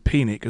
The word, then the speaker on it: Penick: That was where Harvey Penick, as I believe it is correctly pronounced.